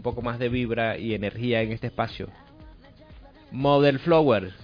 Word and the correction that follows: Mother Flower: ¿lo dije bien? – Los Mother Flower. – Los Mother Flower, sí lo dije.